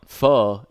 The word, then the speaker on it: pho: Pho is, I mean, the best part about it is you pronounce it pho. – Yeah, yeah, so, but this is pho. – Yeah, it's only spelled pho, bro. – Right. – It's pronounced pho.